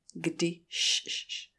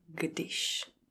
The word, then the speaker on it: když: A pozor, e, slovo když vyslovujeme jako g, d, když, kdy, když, takže g a ne k a na konci š a ne ž. G, když, když, když, když.